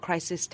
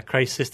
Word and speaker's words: Chryseis: Yeah, it's called Crisis Tan. – Oh, her name is Crisis Tan. – Yes, it's spelled C-H-R-Y-S-E-I-S. – Yeah, Crisis Tan.